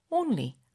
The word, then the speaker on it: only: only 너무 네이셜 사운드가 콧바람이 너무 들어갔다고 그냥 자연스럽게 힘 빼고 only only 이렇게 하라는 거예요.